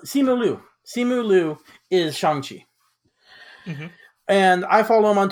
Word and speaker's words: Shang-Chi: He's actually pronounced Shang-Chi. Apparently, he's just been saying it white the whole time.